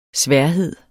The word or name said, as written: sværhed